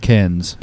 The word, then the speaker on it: Cairns: But there's actually an R in there and no one knows what it means and mispronounces it all the time. – Right. – So that's just I been mispronouncing it today? – No, not at all. Not at all. – Are we going with Cairns? – That's good. That's perfect.